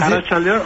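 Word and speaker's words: Arratsalde on: Arrachaldeón, agachaldeón, ¿cómo es? ¿Cómo se dice? – Arrachaldeón o Gabón.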